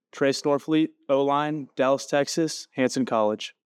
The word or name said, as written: Trace Norfleet